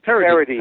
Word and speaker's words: Paradis: Paradis. Paradis is how you say that. – Okay, Paradis. Because um, it's French. And, it looks but, like paradise okay. without the s, without the e on well, the, the Paradis.